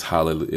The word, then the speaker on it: Hallelujah: So that's why when you um, when you look at it, and it's, and it's spelled Hallelujah, spelled with a J, but it's not said with a J. It's Hallelujah.